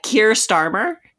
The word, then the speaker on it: Keir Starmer: I don't even know how to say that. Keir Starmer. Did I say that right? – Yeah, I think you got it.